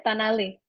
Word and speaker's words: Tanaily: Tanaily.